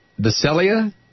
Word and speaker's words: Visalia: Michelle is in Visalia, California.